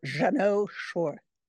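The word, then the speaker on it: Jeannot Szwarc: Jeannot Schwark? – It's Jeannot Schwark.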